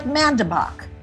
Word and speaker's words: Mandebach: It's like Mandebach. – Mandebach. – If you're from the Midwest.